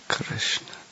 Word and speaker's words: Krishna: Krishna. Krishna. – And the R is no. The R is also short in Krishna. Very short, actually.